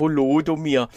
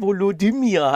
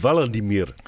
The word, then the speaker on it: Volodymyr: Volodomir. Ja, ähm. – Äh, Daran so schlimm. Weil es einfach Volodymyr heißt. Also der, der, die dritte Silbe ist einfach. Es heißt eigentlich Valadimir.